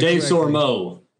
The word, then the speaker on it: Desormeaux: Uh, but, but Michael Desormeaux, I hope I pronounced that. Dave Sormo.